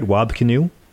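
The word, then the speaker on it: Wab Kinew: This is Wab Kanu. Am I saying his name right? Wab Kanu? – Yeah.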